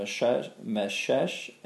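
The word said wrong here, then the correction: Meshech: and Taras. – Meshach. – How do you say it? – Meshach. – Okay. What she said.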